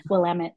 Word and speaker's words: Willamette: Willamette. It's very common. Willamette. That's yeah. When you know someone's not from Oregon.